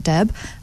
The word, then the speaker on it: Deb: So just go and look up Shuvra Deb. I'll spell that S-H-U-V-R-A. My surname Deb, D-E-B, and With You.